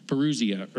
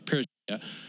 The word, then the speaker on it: parousia: One is erchomai, and one is parousia, or I butchered that word, parousia.